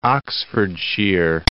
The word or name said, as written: Oxfordshire